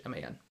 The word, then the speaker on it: Lehman: Ari Lehman. I don't know how to pronounce his last name. Lehman. – That sounds right. – I feel like Lehman is right.